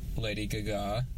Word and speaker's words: Lady Gaga: Number two, Lady Gaga. – That's how you pronounce it, actually. – That's the correct pronunciation.